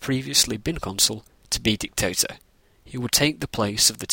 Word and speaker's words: dictator: I'm sure you're asking yourself why I am saying dictator rather than dictator. This is for several reasons, such as that is the actual pronunciation. It is a long A rather than a short A.